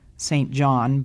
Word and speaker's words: St. John: Now, it's spelled St. John, but I understand that the British pronunciation is St. John.